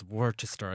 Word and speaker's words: Worcester: It's set in Worcester, which is spelt in some way that I do not even know how they get that pronunciation. Like it's Worcester, I don't know what it is.